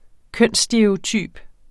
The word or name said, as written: kønsstereotyp